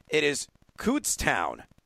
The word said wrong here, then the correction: Kutztown: correct? – No. – Kutztown. Like foot. Kutztown.